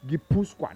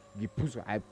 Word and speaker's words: Gipuzkoa: Gipuzkoa. Now the name, in fact, any other pronunciation is wrong. Gipuzkoa. I don't insist. The pronunciation is Gipuzkoa.